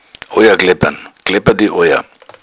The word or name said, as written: kleppern